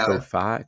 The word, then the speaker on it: Ko-fi: I know there's like a coffee tie-in with that thing. So it's supposed to be Ko-Fi, Ko-Fi.